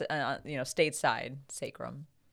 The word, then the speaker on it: sacrum: I mispronounced it as sacrum. So the actual terminology is, or the pronunciation should be sacrum.